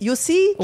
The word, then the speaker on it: Jussi: Wait, and would it be pronounced Jussi? – Jussi, J-U-S-S-I, Jussi Valtonen.